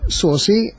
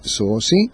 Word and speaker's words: saucy: Sewa, mela, sosi għandna l-aċċent ħalli nkunu eżatti fuq l-ewwel sillaba, sosi, ġejja mill-Ingliż sosi, S-A-U-C-Y, li tfisser l-ewwel nett wieħed li jidher smart u li jista' joqgħod jitkessaħ ftit.